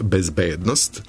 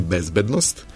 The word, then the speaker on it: bezbednost: Od domaćih reči koje su akcenatski dubleti, izdvojio sam reč bezbednost, a dozvoljeno je i bezbednost.